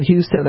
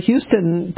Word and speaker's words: Houston: I have to get off at Houston, and I'll, you know, I've lived here long enough. I say no, it's spelled Houston, it's pronounced Houston.